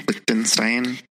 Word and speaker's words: Lichtenstein: And this is where we see the Lichtenstein pain. Am I saying it right? – Lichtenstein.